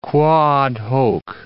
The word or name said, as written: quoad hoc